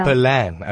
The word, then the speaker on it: Pollan: I'm not sure if I'm pronouncing his name right. Michael Pollins? Is that correct? – Michael Pollan, Pollan, all right.